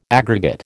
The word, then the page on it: aggregate: American English